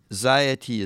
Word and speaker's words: Xyity: And just to repeat for everybody, Ziety is spelled X-Y-I-T-Y.